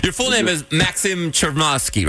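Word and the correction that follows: Chmerkovskiy: right? – Nope, you misspelled it. You misspoke it. – There's a lot of wrong there, but, you know, a lot of wrong there. – It took like 15 years to perfect the name, and but Chmerkovsky, it's a tough one.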